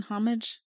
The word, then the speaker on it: homage: Do you actually say the H in homage? Okay, just checking. – I don't know. I'm not the person to ask on how to pronounce words. I'm the last person to ask about this. – The H is silent.